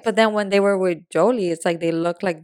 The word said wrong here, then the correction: Yoli: It's Yoli, babe. – I always say her name wrong. What is it? – Yoli.